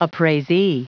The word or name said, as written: appraisee